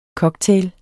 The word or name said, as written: COCKTAILENE